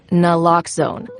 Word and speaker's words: naloxone: For the record, it's Naloxone. Nuh, like, is Naloxone addictive? Nuh-uh. Lox, like Naloxone keeps humans safe, like locks keep bikes safe. And own, like Naloxone can save lives in our very own communities.